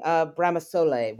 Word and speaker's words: Bramasole: And you talk about visiting Bramasole, which I'm assuming is, am I pronouncing that correctly? – Correct. Perfect.